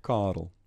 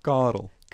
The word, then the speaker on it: Carel: But Carl, how do you really pronounce, let's just get this right, how your mum pronounces your name in South Africa? – Carl. – It's lovely, and I can't say it. Go again. – Carl.